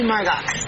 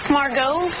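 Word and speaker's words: Margaux: So you must be Margox. – It's Margot, the ex is silent.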